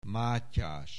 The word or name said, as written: Mátyás